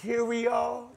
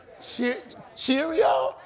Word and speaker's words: curio: Anybody in the house got a curio? Did I say that right? Or did I say it wrong? Cheerio? Curio. Curio. Curio. Not the cereal.